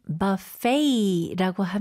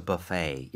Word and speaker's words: buffet: 그래서 buffet도 스펠링이 B-U-F-F-E-T이지만 그 T를 발음하지 않아요. – 아, 오케이. Okay. So buffet.